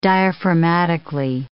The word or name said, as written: diaphragmatically